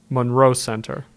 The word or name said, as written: Monroe Center